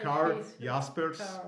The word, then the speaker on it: Karl Jaspers: especialmente Karl, Asp, Karl, Karl Jaspers, pô, eu treinei a pronúncia e errei. é Karl Jaspers, alemão.